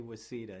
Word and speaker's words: WCEDA: Yeah, generally people do say WCEDA. I think that's probably the easiest way to pronounce it.